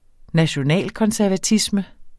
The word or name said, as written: nationalkonservatisme